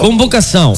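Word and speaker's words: convocação: ¿Cómo se pronuncia eso? ¿Convocasao? – Convocasao. – Sí, convocasao.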